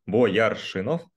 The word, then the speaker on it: Бояршинов: А, фамилия немножечко по-другому читается. Бояршинов.